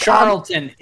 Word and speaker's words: Charlton: Come on, Charleston. Sure. I'm stuck. – It's Charlton. – Charlton. It's Charlton. I can't.